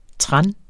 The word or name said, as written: trans